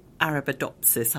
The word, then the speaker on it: Arabidopsis: So where exactly are your Arabidopsis, have I pronounced that right, seeds on Artemis? – So you pronounce it really well.